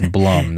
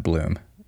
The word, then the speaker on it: Blum: This is a Blumhouse film, and it's Blum, not Bloom.